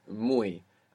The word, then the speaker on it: muy: The word for very is muy, and it's spelled M-U-Y. The Y, you can imagine it like an I. Muy.